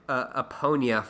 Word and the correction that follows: Epona: from Legend of Zelda. – Oh, I'm sorry? – I think I still did it. – Epona? – Epona. – Yeah. – Well, what I said was Eponia, which is totally off.